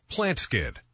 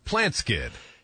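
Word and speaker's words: Plant Skydd: Plant Skid. That's spelled plant, S-K-Y-D-D. Plant Skid.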